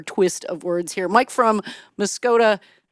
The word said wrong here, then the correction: Muscoda: Maggie, that's pronounced Muscoday. – Muscoday. I get this wrong every time, Mike. You keep correcting me, and one of these days I'll get Muscoday right.